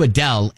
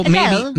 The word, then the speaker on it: Adele: So Adele. maybe take the hard A sound and make it a little bit softer, more like an rather than... – More emphasis on the D. Adele.